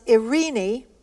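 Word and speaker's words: irene (εἰρήνη): The Greek is pronounced irene, and it means peace, harmony, tranquility, and health.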